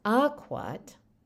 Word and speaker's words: akwat: So it's akwat, initial syllable stress.